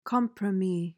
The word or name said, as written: compromis